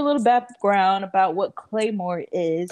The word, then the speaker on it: Claymore: That's how you pronounce it, right? Claymore? – That's exactly how you say it. Claymore, not to be confused with those things that you set down in Call of Duty to blow people up.